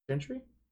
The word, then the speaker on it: Gentry: Gentry? – Gentry. Okay. G-E-N-T-R-Y.